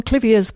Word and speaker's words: Clivia: Back on Clivia's Clivias, and Richard Harlow from Albany says, "Hi, Geraldine, Mark. No matter what Mrs. Clive was called, Clivia rhymes with Trivia and Olivia."